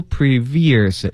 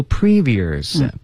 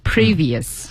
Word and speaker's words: previous: 那这里呢先和朋友们说一下它的这个发音，首先要注意一般情况下重音放在前面。 – 啊，所以您不要读 previous，要读 previous。 – previous。